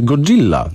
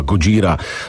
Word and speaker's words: Gojira: Co ciekawe, nazywali się Godzilla do 2000 roku. Okazało się, że że to jest niewłaściwa amerykańska wymowa japońskiego słowa Gojira,